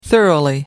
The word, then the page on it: thoroughly: American English